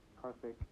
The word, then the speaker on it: Karthik: Karthik. It's like a TH. The H is silent.